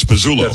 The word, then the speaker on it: Pizzullo: Get that. Pizzullo. We actually have the L's and the Z's backward on here. So it's Pizzullo.